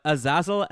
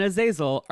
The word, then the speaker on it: Azazel: I mean, so Azazel, for people who are not familiar, and by the way, much like Gala and Gala, Azazel and Azazel are both correct pronunciations of the Hebrew and Arabic name.